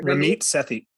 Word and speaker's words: Ramit Sethi: Ramit Sethi.